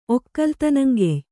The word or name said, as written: ಒಕ್ಕಲ್ತನಂಗೆಯ್